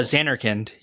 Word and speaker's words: Zanarkand: Zanarkand. Is that right? Right pronounced? Uh, Zanarkand. Yeah.